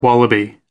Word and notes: wallaby: American English

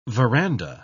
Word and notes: verandah: British English

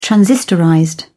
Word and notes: transistorized: British English

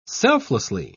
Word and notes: selflessly: British English

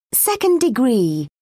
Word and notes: second degree: British English

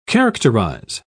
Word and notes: characterise: British English

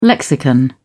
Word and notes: lexicon: British English

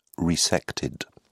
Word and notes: resected: British English

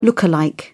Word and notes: look-alike: British English

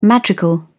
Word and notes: madrigal: British English

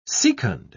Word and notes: secund: British English

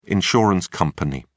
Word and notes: insurance company: British English

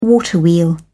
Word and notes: waterwheel: British English

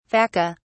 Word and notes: vaca: British English

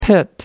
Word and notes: pit: American English

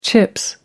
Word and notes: chips: British English

